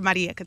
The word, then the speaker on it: Marieka: Dr. Marieka Thomas.